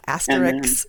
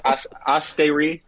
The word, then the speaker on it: Astérix: Not asterisk. Like, yeah, well, Americans. The American version would be asterisk, but yeah, I think it's Asterix.